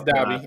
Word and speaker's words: Dabi: I don't know how to say it. I, I yeah, it, it's Dabi. It's Dabi.